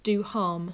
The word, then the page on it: do harm: English